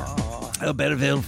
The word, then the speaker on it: Albertville: The 1992 Winter Olympics Games opened in Albertville, France. Am I pronouncing that correctly? – Sure. – Albertville, France.